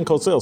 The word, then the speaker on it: Cosell: The Korean Cosell. Did I say that right? Yes, I did. The Korean Cosell.